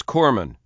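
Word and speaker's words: corpsman: It, it, it, it is Corman. You don't pronounce the S.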